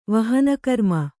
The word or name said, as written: ವಹನಕರ್ಮ